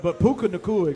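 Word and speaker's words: Puka Nacua: This cat last night, man, Pika Nakua, I'm sure that I'm butchered that in some form, but Puka Nakua, excuse me, Puka, this cat here, 9 catches, 160-plus yards.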